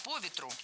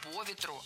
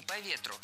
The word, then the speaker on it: по ветру: Держать нос по ветру – это фразеологизм, в котором перенос ударения на предлог действительно требуется. Именно держать нос по ветру, а не по ветру.